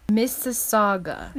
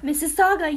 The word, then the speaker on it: Mississauga: It's kind of like Mississippi, but with a G. Mississauga. How do you pronounce this word? Oh, there's a video. Mississauga. – Mississauga, yeah, that's how I heard them say it.